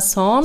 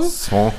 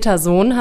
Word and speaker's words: Sohn: Ähm, was hatten wir jetzt rausgefunden? Peter Son. Son. Ähm, Sohn haben wir es erst auf Deutsch ausgesprochen,